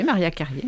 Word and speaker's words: Maria Carrier: Comme ça qu'on vous prononce votre nom. – Tout à fait, Maria Carrier, merci.